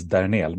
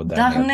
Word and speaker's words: Dernehl: Dernel. Min pappa födde Tyskland så det är tysk, tyskt uttal på Dernel.